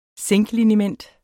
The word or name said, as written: zinkliniment